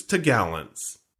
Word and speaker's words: topgallant: On a square-rigged sailing vessel, a topgallant, which is actually how it's spelled, so I'm just kind of phonetically saying it there, but its topgallant alone is actually pronounced t'gallant. And then the topgallant sail, or t'gallant sail, is pronounced t'garnsel.